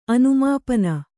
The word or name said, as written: ಅನುಮಾಪನ